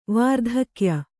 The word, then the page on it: ವಾರ್ಧಕ್ಯ: vārdhakya